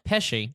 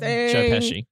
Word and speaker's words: Pesci: I can't say the last name. – Pesci. – Thank you.